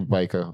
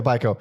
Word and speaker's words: Biko: Biko? Steve? – Yes. – However you pronounce that. How do you pronounce it? Yeah, Steve Biko. – Yeah, Biko.